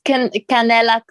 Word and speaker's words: Canela: So, you know, your whole clothing, by the way, guys, her label is called, do you want to say it so I don't say it wrong? – Is Canela Clothing. So Canela means cinnamon in Spanish and Italian.